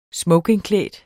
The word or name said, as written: smokingklædt